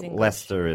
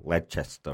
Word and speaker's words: Leicester: Yeah, it's like Leicester is really Leicester or something.